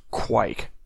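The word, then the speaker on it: Kveik: Okay, apparently it's pronounced Quike. – Yes. – According to, I don't like that, I'm going to go with Kvik.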